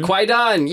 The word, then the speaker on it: Kwaidan: Kwaidan. – Kwaidan, yes.